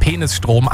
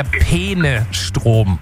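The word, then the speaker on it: Peenestrom: Und es ist, also ich bin mir sicher, sie spricht es absichtlich so aus, weil sie sagt ja eindeutig Penisstrom, aber es geht um den Fluss Peene und es ist der Penestrom.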